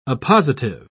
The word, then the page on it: oppositive: British English